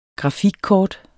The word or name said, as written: grafikkort